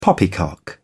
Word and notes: poppycock: British English

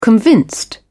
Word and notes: convinced: British English

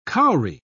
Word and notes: kauri: British English

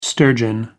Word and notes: sturgeon: American English

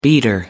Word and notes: beater: American English

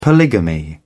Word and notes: polygamy: British English

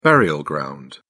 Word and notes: burial ground: British English

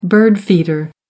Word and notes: bird feeder: American English

